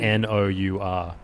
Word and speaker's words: Nour: A, a word that was spelt like sour but with an N. – Where at the beginning yeah, like mm. N O U R. Nour.